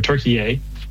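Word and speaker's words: Türkiye: or Turkey-ay, as the proper pronunciation.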